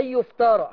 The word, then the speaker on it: يفترى: أن يفترى حرف الراء ورا منه إيه؟ – ياء. – هي ياء ولا ألف؟ هي صحيح مرسومة ياء بس بتنطقيها إيه؟ – بنطقها ألف.